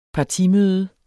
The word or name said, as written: partimøde